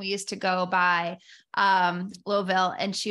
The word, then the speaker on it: Louisville: And you are from Louisville, Kentucky. Did I say that right? – You said it. There's so many ways to say it. That'll work. – I remember I used to say it Louisville.